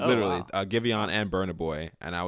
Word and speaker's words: Giveon: I've been listening to Giveon a decent amount. I think that's how you say his name. – Yeah, it is.